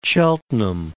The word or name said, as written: Cheltenham